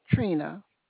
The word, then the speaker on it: Treantia: T-R-E-A-N-T-I-A. – Yes, ma'am. – Pronounce that first name for me. – It's just pronounced Trina.